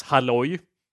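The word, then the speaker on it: hallå: Alla. How do you say that in Swedish? – That says, alla. – It sounds more Middle Eastern. It's halloy.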